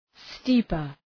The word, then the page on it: steeper: ‘sti:pər